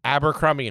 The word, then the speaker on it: Abercrombie: Abercrombie and Fitch.